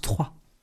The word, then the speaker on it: Troyes: La ville se prononce Troyes.